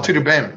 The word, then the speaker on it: Tudo Bem: Oh, Tudo Bem, yeah. – Yeah, if I'm pronouncing it right, yeah. – Like, no, you got it.